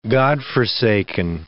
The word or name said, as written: godforsaken